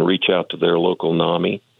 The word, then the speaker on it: NAMI: I like that NAMI rhymes with mommy.